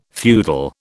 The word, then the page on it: feudal: British English